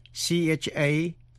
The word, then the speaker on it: chamois: Nhưng có một số chữ, trong đó CH phát âm là sh, như trong tiếng Pháp chữ chanté, nghĩa là hát. Đó là những chữ như chamois.